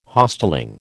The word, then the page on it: Hostelling: American English